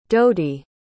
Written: dodie

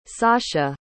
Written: sacha

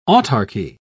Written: autarky

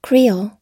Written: creole

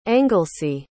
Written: anglesey

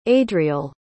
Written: adriel

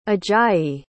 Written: ajayi

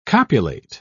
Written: copulate

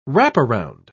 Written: wraparound

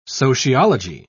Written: sociology

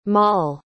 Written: Maule

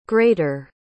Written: greater

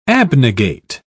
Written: abnegate

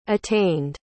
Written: attained

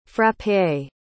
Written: frappé

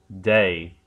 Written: day

